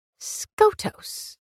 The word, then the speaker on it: Skotos: Skotos, Princess Analia interrupted. – It's pronounced like Scotus. You know how it's pronounced, maiden. Do so correctly.